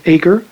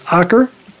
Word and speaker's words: Acre: which could be mispronounced Acre, but it's Acre.